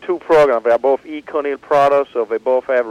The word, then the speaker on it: Econyl: E-C-O-N-Y-L. – All right. – Which could be pronounced Econil, but we prefer Econil, so that's the way we're going to call it.